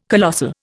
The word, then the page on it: colossal: Indian